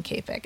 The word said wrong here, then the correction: Kapic: It's Kopic.